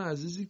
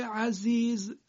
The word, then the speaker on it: عزیز: کلمه عزیز زش زی فارسیه، یعنی همون جوری که ما ز رو در فارسی تلفظ میکنیم، اینجا هم کلمه عزیز زش باید همون جوری تلفظ بشه.